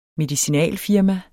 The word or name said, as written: medicinalfirma